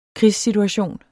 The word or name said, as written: krigssituation